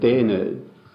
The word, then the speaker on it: Daene: How do you spell that? – Dehne. D-A-E-N-E. Yeah.